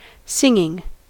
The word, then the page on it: singing: American English